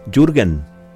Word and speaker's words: Jürgen: Jürgen, Jürgen, Jürgen. Tampoco sé pronunciar alemán. Soy bastante bruto, ¿no? Y esencial. Pero bueno, es Jürgen. Sí, es así.